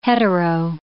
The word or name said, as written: hetero